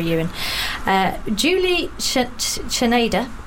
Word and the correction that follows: Czerneda: That's Czerneda.